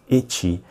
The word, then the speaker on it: Ecchi: Elder God, Ecchi, pronounced Ecchi,